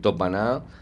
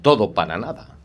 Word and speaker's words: todo para nada: Cada uno en su casa puede hablar como quiera, decir topaná o topato, lo que quiera. Pero cuando se está ante un micrófono y hablando en público, yo creo que hay que pronunciar todo para nada en vez de topaná.